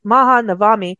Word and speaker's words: Mahanavami: That's it. Mahanavami.